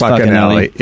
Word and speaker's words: Facinelli: It's Facinelli. – Yeah. – Pronounced Facinelli.